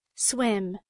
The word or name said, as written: swim